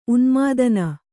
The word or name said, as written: ಉನ್ಮಾದಿನಿ